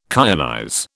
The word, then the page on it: kyanize: British English